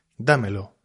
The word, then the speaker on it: dámelo: En esos casos, si el énfasis recae en la primera, dímelo, dámelo. Tenemos que poner tilde, ¿vale?